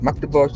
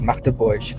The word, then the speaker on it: Magdeburg: Es heißt nicht Magdeburg, sondern das ist Machteburg.